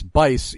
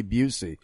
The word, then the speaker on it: Busey: But you mispronounced Josh's last name. It's Bice, even though it's spelled like it should be Busey.